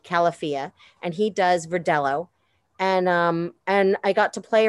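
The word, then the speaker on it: Verdelho: But tell me about the Verdejo first. – Well, it's actually pronounced Verdello. It's not a Spanish name.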